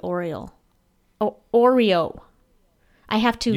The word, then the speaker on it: Oreo: Last week, Todd Takei at Tooth Takei said, when says Oreo, I hear Oriole.